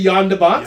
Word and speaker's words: Jan de Bont: I would say it's a soft J, Jan. – Yeah. – Because if it was Jan, it'd be J-E... – A-N, yeah. We're going to say Jan de Bont.